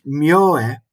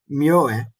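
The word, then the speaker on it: Myōe: En nog even terug naar die, naar die Miu. Dat was, begrijp ik, een vooraanstaand figuur in het esoterisch boeddhisme. Ik zou wel even willen vermelden dat, dat zijn naam wordt uitgesproken als Mieu, Myoe.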